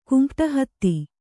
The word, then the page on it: ಕುಂಪ್ಟಹತ್ತಿ: Kannada